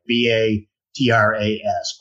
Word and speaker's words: Batras: They flew into the military air force base, and Carlos was given over to Guatemalan Air Force colonel named Antonio Batras. I think I'm saying that right. B A T R A S, Batras.